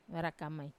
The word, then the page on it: vara kamai: Wamesa